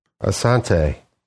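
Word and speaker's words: Assante: Asante. – Asante? Is that how you say it? – Yes.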